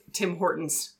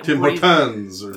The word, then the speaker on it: Tim Hortons: Tim Hortons. – Yeah, tend to breeze over those middle syllables like Tim Hortons. You don't hear it? Where it's like Tim Hortons. All about skipping letters in words, yeah.